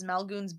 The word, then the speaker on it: Maelgwn: Through a mysterious power, he renders Maelgwn's, I'm reading it phonetically, sorry, it's Maelgwn.